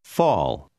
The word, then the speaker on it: fall: Fall. – La lettera A seguita da due L generalmente si pronuncia come una A preceduta da una W.